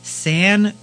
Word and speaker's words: Sangiovese: Sangiovese, that's how they Sangiovese.